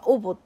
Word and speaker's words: 姑獲鳥: でもねこうあ「おぼ」って読むみたいなことが書いてある